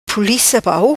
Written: Pulica Pau

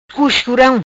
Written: Cuscuram

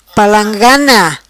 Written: Palangana

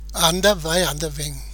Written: Anda Vai Anda Vem

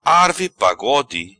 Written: Arve Pagode

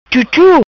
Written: Chuchu

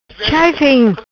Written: Chai feng

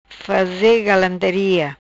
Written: Faze Galantaria

